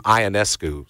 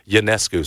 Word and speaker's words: Ionescu: used to say her name Ionescu. So to make I, have listened to it since, and it sounds like the pronunciation now is Ionescu.